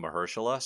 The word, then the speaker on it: Mahershala: I've been saying Mahershala, so if I'm wrong here... – Oh, no, you're right.